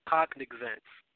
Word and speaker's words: cognizance: Because the same way you said it was the same way I was saying it until I looked at the pronunciations on the internet. But the name of the precept is called cognizance. It's cognizance. Cognizance.